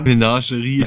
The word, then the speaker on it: Menagerie: Wieder der gute Mengari, wie wird das ausgesprochen? Mengarin, Wächterin? – Menagerie, ich habe mich für uns gesagt. – Ja, Menagerie, Wächterin.